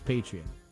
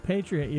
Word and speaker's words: Patreon: I think it's Patreon. – Okay, Patreon.